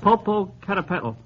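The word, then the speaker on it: Popocatépetl: Yes, but you'll have to pronounce it. – Popo Catapetl. – Nice work. That's correct. Six, Bolo. B-O-L-O.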